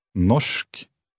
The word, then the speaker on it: norsk: Norsk. Norsk. Norsk. – Did you hear the sh sound at the end?